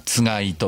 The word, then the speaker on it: 番: 同じ字でつがいと読みます。